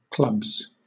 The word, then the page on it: clubs: British English